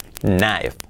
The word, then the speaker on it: knife: Knife. Knife. Knife. Fijaos que no, la casa no se pregunta, no se dice knife, no.